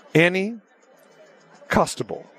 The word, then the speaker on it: Annie Costabile: The Chicago Sun-Times very own, all right, Annie Costable.